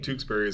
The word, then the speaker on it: Tewkesbury: We Googled it this time. And it said Tewkesbury. – Tewkesbury is what it said.